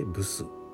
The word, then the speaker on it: 附子: 生薬の武士という字を書いてブスと読む